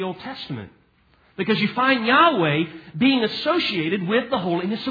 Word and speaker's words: Yahweh: Though it's pronounced, to the best of our understanding, Yahweh. And